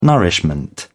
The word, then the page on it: nourishment: British English